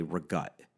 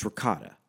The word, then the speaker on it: ricotta: Like she'll say like ricotta, she'll say regut. What the fuck is regut? It's ricotta. No, you say it like that because you're not Italian. No, I say it like that because I'm pronouncing the word fucking correctly.